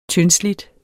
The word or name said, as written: tyndslidt